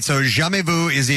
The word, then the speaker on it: jamais vu: It is French. How would you pronounce that right there? Because I do not know. – Oh, it's a jamais vu, which means never seen.